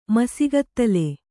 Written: ಮಸಿಗತ್ತಲೆ

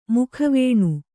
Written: ಮುಖವೇಣು

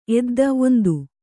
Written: ಎದ್ದ

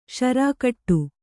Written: ಷರಾಕಟ್ಟು

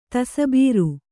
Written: ತಸಬೀರು

